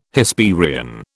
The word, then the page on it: Hesperian: British English